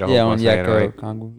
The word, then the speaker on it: Okongwu: Okongwu, if I'm saying it right. – Okongwu, yeah.